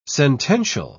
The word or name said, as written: sentential